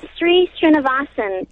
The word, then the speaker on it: Sri Srinivasan: Yeah, Sri Srinivasan.